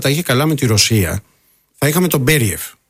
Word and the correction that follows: Μπεριέφ: Μπέριεφ, πού τονίζεται πρώτα απ' όλα για να το λέμε και σωστά. – Νομίζω το λένε Μπεριέφ. – Μπεριέφ, λοιπόν. – Νομίζω τώρα είναι ναι. Από ό,τι έχω διαβάσει, τέλος πάντων, έχω δει να τονίζω στον Μπεριέφ.